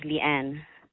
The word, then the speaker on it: Leann: Yes, it's my email is Leanne. – Okay. – L-E-A-N-N. – L-E-A-N-N.